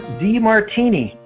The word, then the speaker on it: Demartini: and Dr. John DeMartini.